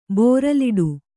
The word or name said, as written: ಬೋರಲಿಡು